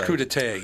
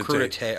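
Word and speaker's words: crudité: That's crudite. – That's crudite. – Crudite. – Oh, crudite.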